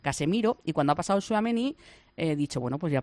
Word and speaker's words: Tchouaméni: y le he dicho Tchouaméni y se ha dado la vuelta, ha sonreído, me ha guiñado un ojo y nada, seguido.